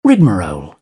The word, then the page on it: rigmarole: British English